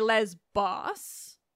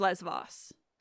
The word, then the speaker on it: Lesbos: And in modern Greek, it's Lesvos. But I know that in Greece, you pronounce it Lesvos, even if you're talking in ancient Greek.